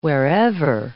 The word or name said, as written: wherever